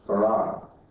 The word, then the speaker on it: Fard: And I believe that the teacher of my father, you'll find in the Encyclopedia Britannica under the name Farr, F-A-R-D, pronounced Farrar.